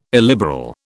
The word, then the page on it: illiberal: British English